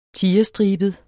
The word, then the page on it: tigerstribet: [ -ˌsdʁiˀbəð ]; [ -ˌsdʁiːbəð ]